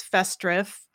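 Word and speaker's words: Festschrift: That Festdrift, is that how the correct pronunciation? – Yes. – Do you know? – Yes. Fest is festive, like festive. – Mm-hmm. – And Schrift is like written in the sense of something written, a book.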